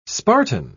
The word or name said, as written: spartan